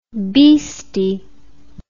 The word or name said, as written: bhishti